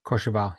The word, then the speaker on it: Courchevel: Is it Courchevel? – Courchevel, yeah.